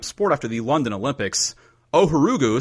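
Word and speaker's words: Ohuruogu: Ohorugu.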